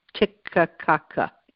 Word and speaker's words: Titicaca: I always mess up this pronouncing this. Titicaca in Bolivia and Peru.